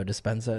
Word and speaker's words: Dispenza: So if you're looking for a good audiobook, Dr. Joe Dispenza, is that how you pronounce it? – Yeah, that's a good one.